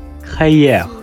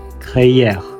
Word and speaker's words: crayère: 今天我们来复习一下白垩酒窖的法语发音 h e y e r 开业，开业，你学会了吗？